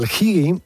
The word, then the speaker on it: hygge: Mira, pues el higi se escribe H-Y-G-G-E, ¿no?